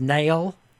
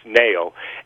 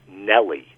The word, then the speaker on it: Nelle: It was, and help me with the pronunciation of this, it was Nail. – That's a very Southern, very good pronunciation, right, spelled N-E-L-L-E. That would come out in a Southern accent, just as you said, as Nail.